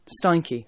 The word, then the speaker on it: Steinke: We were chatting over the phone and I misheard her a little bit, so Steinke, I believe, is how you actually are supposed to pronounce it.